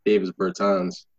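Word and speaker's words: Dāvis Bertāns: So far, Avery Bradley and fucking Davis Bertans. – Yeah. – But yeah. I think you can't say Davis and then say Bertans. Like, you got to give him a long A on each one or something.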